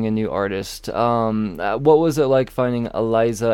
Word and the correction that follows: Elisa: And I'm gonna probably butcher the last name, Menegal. – Meneghel, and it's Elisa Meneghel. – Elisa, I butchered the first name. Elisa Meneghel. All right, gotcha.